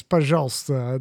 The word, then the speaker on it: пожалуйста: And he, he goes to say please in Russian, which I know the word for please in Russian is пожалуйста. That's how you say it. And he goes, пожалуйста.